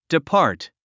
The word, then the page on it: depart: dipɑ’ː r t; ディパート